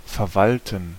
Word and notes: manage: /ˈmæn.ɪdʒ/; /ˈmænədʒ/

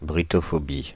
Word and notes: brittophobies: /bri.tɔ.fɔ.bi/; France (Île-de-France)

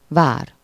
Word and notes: château: [œ̃ ʃa.to]; /ʃɑ.to/; [ʃato]

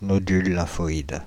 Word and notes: nodule lymphoïde: France (Île-de-France); /nɔ.dyl lɛ̃.fɔ.id/

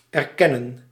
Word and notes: acknowledge: /əkˈnɒlɪdʒ/; /ækˈnɑl.ɪdʒ/

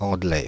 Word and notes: Anglais: Paris; France (Ouest)

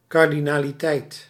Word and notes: moc: [mɔt͡s̪]

